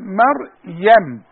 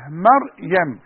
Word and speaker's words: مريم: كلمة مريم انا عايز اعلم الناس ازاي ينطقوا مريم. ما اقولش مريم وكمان ما اقولش مريم. ما في الاسماء زي بعضه. انما احنا عندنا سورة اسمها سورة مريم. اقول ما مريم. ما مريم.